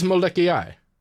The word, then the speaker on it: Molokai: Oh no, it is Moleki Ai. Like that's how you pronounce it.